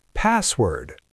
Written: password

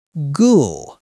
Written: ghoul

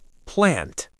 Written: plant

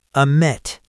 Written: omit

